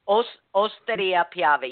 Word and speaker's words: Osteria Piave: Osteria Piave. You don't pronounce the H.